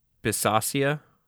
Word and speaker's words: Bisaccia: Rich Bisaccia. – Rick Buchhagen? What? – Rich Bisaccia. – Bisaccia? – I think is how his name is pronounced.